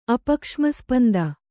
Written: ಅಪಕ್ಷ್ಮಸ್ಪಂದ

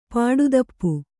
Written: ಪಾಡುದಪ್ಪು

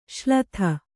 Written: ಶಲದಿ

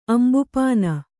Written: ಅಂಬುಪಾನ

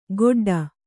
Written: ಗೊಡ್ಡ

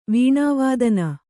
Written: ವೇಣುವಾದನ

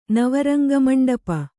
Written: ನವರಂಗಮಂಡಪ

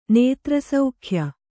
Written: ನೇತ್ರಸೌಖ್ಯ

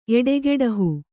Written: ಎಡೆಗೆಡಹು

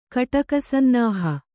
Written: ಕಟಕಸನ್ನಾಹ

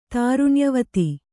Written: ತಾರುಣ್ಯವತಿ

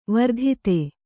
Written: ವರ್ಧಿತೆ

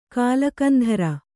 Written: ಕಾಳಕಂಧರ

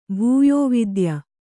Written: ಭೂಯೋವಿದ್ಯ

